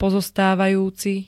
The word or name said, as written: pozostávajúci